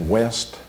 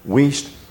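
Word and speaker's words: Wuest: Do you know how to pronounce his name? Is that West? – Wiest. – Wiest Word Studies.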